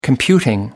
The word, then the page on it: computing: American English